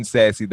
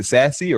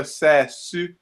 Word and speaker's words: Sasse: Ben Sasse, is it Sassy or Sassy? – I think it's Sass. – Sass. – Let's go with like a French, like a Sass. Say? Like Sass.